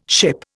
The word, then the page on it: ship: Indian